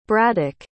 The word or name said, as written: braddock